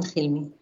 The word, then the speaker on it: Hilmi: In the novel, we have the character of Liat, and she meets Hilmi. Is that how you pronounce it? – Exactly, both Liat and Hilmi.